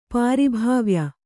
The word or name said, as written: ಪಾರಿಭಾವ್ಯ